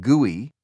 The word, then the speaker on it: GUI: This graphical user interface, or GUI, pronounced GUI, was facilitated by another concept pioneered at Xerox Park, bitmapping.